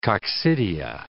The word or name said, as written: coccidia